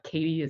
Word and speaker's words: Cady: But a couple that come to mind, Katy Hill Lodge, which is a newly renovated property. If people are trying to look it up, Katy is spelled C-A-D-Y.